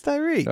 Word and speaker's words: Tyreke: Are you calling him Tyreke? – What's his name? Isn't it Tyreke? Tyreke? Is it Tyreek? – It's Tyreek.